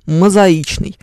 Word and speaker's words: мозаичный: Есть слово «мозаика», но почему-то э, он мозаичный. А, вот, они мозаичные, тоже странная штука, правильно? Ударение сползает, э, объяснения этому нет.